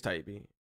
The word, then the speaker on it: Taibbi: Matt it, Matt Taibbi, am I pronouncing it right? I believe so. Yeah. – Taibbi, Taibbi. – I think it's Taibbi.